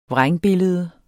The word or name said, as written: vrængbillede